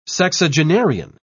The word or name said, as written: sexagenarian